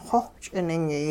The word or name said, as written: xowh ch'iningyay